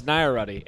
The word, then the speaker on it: Nairati: Yes, Nairati. It rhymes with Maserati.